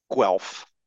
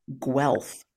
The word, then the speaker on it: Guelph: Guelph. Am I pronouncing that correctly? – Guelph. – Guelph.